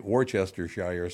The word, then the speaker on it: Worcestershire: Now here's one that I love because you hear people say instead of Worcestershire sauce, they say Worcestershire sauce. like, no, it's Worcestershire. It's not Worcestershire sauce.